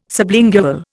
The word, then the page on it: sublingual: Indian